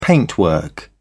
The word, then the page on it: paintwork: British English